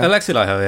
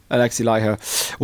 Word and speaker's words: Alexi Laiho: Yeah, and I, God love me, I presume it's pronounced Alexi Laiho, is it? – Yes. – How do you say it? Laiho. – Alexi Laiho. Yeah. – Alexi Laiho.